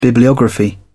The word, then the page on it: bibliography: British English